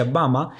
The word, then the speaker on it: Burma: Pero si lo decimos en inglés, Burma se pronuncia Bama y en, en, birmano el nombre del país es Bama.